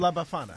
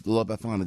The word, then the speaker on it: La Befana: La Befana. – Yeah, La Befana.